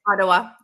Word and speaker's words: Ottawa: Ottawa. – Ottawa.